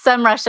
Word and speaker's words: Semrush: I was just telling Diana, I got followed around the internet this morning and there was an audio ad, and that's how I learned that it's Semrush. And I thought it was just me having mispronounced it all this time.